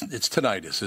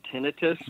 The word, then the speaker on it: tinnitus: You know, I don't, you and I didn't talk about, people say tinnitus and tinnitus. I think it's tinnitus, isn't it? – I say it's, you know, doctors pronounce it tinnitus. – They do, yes. – But I haven't. But tinnitus is also an acceptable pronunciation.